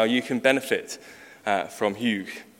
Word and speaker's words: hygge: I'm reliably informed by YouTube that that is how you pronounce H-Y-G-G-E in Danish, Hyug.